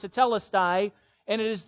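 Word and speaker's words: tetelestai (τετέλεσται): It was a Greek word pronounced tetelestai. Tetelestai.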